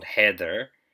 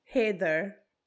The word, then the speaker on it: Haider: If you pronounce it properly, it's called Heather. – Yeah. – But it's spelt Haider. H-A-I-D-E-R. – Heather. – Heather. – Like, hey there, Heather. – Yeah. It's, but if you say it, I think it actually sounds like you're saying Heather.